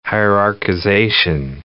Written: hierarchization

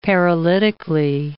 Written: paralytically

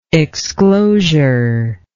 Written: exclosure

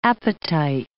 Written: appetite

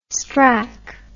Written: strack

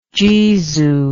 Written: jesu

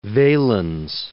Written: Valens

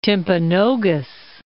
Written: Timpanogos, Mount

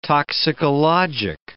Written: toxicologic

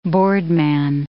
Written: boardman

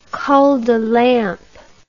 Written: cul de lampe

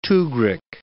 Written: tughrik

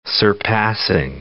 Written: surpassing

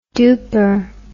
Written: duper

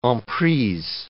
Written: en prise

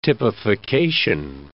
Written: typification

